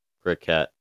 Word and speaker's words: croquet: You said C-O-R-O. – No, C-R. So if I say that, why are you saying core? – Oh, there's nothing in between the C and the R. Croquet. Is that how you say it? – Croquet.